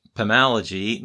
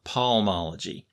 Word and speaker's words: Pamalogy: So take note of the fact that there's no L before the M in Pamalogy. It's pronounced Pamalogy, not palmology.